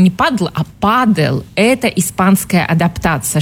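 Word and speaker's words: pádel: И так появилось слово «падл» с ударением на «а», официальное название нового вида спорта, далекий 69-й год.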